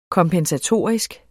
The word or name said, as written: kompensatorisk